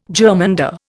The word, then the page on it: germander: Indian